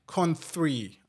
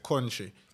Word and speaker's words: Countree: Countree hype, con three hype. You see, cause sometimes people say country. Con what? It's, it's con three. It's spelled different.